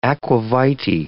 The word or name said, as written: aqua vitae